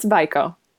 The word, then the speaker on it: WIQO: The company's name is Vico, and everyone kind of pronounces it differently based on, you know, if they're from New York, California, or Chicago. So it's Vico. W-I-Q-O.